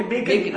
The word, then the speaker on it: BACN: Bacon.